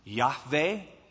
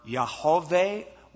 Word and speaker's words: Yahweh: And it would be pronounced Yahweh or Yahweh or Yahoveh. We don't exactly know. We've lost the pronunciation.